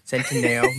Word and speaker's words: Centineo: And Noah Centineo. – Centineo.